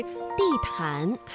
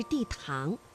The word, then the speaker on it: 地坛: 那么在平时朗读的过程当中啊，你的发音是地坛还是地堂？这个安呢是前鼻韵母，气流呢是从鼻子前方出来的。